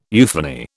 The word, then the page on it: euphony: British English